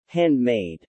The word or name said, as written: handmade